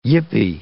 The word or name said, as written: yippie